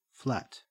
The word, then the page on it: flat: Australian English